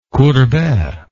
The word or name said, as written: Courbet